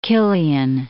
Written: Killian

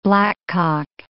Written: blackcock

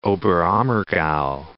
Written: Oberammergau

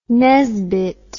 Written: Nesbit

